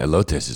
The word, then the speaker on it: elotes: And the other thing that's great that they make, elotes. Is that how you say it? – Yeah, elotes is delicious.